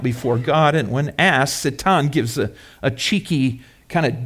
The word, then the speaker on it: Satan: In verse 6, the angels, including Satan, that's the Hebrew pronunciation, I'll probably use that from time to time, Satan,